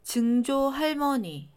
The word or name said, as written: 증조할머니